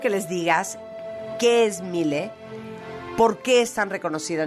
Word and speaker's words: Miele: Y es la marca alemana Miele, que seguramente la han visto, se escribe Miele, pero se pronuncia Miele.